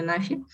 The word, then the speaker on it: НАФИ: А, у нас есть результаты исследования аналитического центра НАФИ или НАФИ, наверное, НАФИ, поставлю ударение НАФИ.